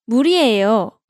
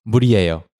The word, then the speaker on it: 물이에요: You can say 물이에요. – 물이에요. – 물이에요. – Note that you just added 이에요 after 물, not before 물. – 네, 물이에요. – 물이에요.